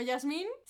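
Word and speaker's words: Yasmine: Eh, pues me llamo Yasmin, se escribe con E, pero no se pronuncia.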